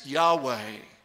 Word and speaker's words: Yahweh: But that tetragrammaton, YHWH, he's saying it's almost certain they pronounced it as Yahweh.